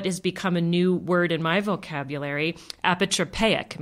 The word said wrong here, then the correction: apotropaic: Am I saying that right? Almost, apatropaic. Apatropaic. I just did it wrong.